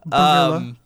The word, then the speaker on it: Barilla: No, Barilla, but thank you. – Barilla. Sorry, I apologize. – I do not... – Yeah, man, he's not from here. – I totally understand. – That's how we pronounce it. – I've had to tell Elgrimmon several times that it is Barilla. – Not Barilla.